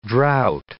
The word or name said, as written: drought